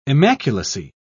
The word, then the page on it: immaculacy: British English